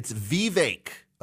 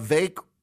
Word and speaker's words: Vivek: Vivek. Vake rhymes with cake. Vivek. The DeSantis people are helpfully pointing out Vivek rhymes with fake.